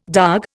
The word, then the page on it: darg: Indian